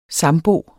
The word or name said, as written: sambo